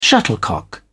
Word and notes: shuttlecock: British English

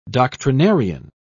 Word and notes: doctrinarian: British English

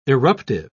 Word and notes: irruptive: British English

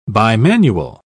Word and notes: bimanual: British English

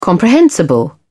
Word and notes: comprehensible: British English

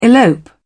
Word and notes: elope: British English

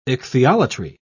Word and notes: ichthyolatry: British English

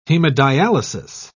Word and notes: hemodialysis: British English

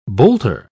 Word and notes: boulter: British English

